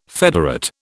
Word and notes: federate: British English

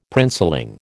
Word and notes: princeling: American English

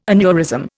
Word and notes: aneurysm: Indian

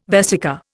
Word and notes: vesica: Indian

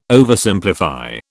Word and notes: Oversimplify: British English

